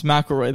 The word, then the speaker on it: McElroy: Yes, McElroy. That's how you say it. – He told me. That's what he replied. He said, like the golfer.